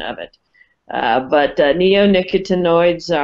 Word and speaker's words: neonicotinoids: So so the uh, the the word is properly pronounced neonicotinoids.